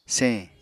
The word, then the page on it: señ: Apatani